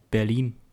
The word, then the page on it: Berlin: German